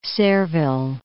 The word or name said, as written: Sayreville